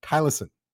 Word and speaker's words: Thylacine: I hope I pronounced the correct name of the, of the uh, tiger correctly. Thylacine. Yeah, you did.